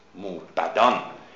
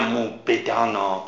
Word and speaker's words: موبدان: موبدان نه موبدانا، توجه کنید به تلفظ، چون در محل قافیه به ما میگه که باید موبد تلفظ کنیم.